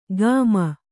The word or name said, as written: ಗಾಮ